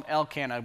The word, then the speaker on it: Elkanah: Chapter 1, verse 21, it says, the man Elkanah, I decided this week I'm going to call him Elkanah because it rhymes with Hannah and it's just easier.